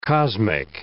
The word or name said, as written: cosmic